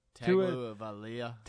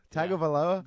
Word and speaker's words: Tagovailoa: How do you say it? – Tagovailoa. – Tagovailoa.